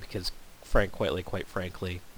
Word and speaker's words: Frank Quitely: is it Frank Quitely? Is that how you say his name? – Yeah.